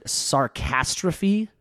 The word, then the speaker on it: Sarcastrophe: But it's I still consider it the intro to, I'm going to make sure I say this right, Sarcastrophe. – That's how I thought it was pronounced.